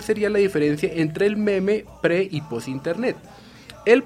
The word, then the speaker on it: meme: Bien, pero sigamos hablando de la palabrita meme, que es un neologismo creado en inglés, pero que en dicha lengua se pronuncia meme.